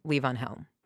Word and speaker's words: Levon Helm: The correct pronunciation, Levon Helm.